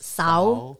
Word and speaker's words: Xauv: So it's spelled X-A-U-V, which is pronounced sow in Hmong, and Hmong da in the white Hmong language, but it's called differently in Hmong joa, lupao, right? – Lupao, yes.